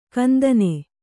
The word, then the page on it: ಕಂದನೆ: kandane